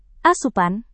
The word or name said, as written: asupan